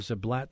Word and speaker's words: Ziblatt: Daniel Ziblatt, thanks for joining us.